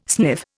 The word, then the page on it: sniff: Indian